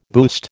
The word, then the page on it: boost: American English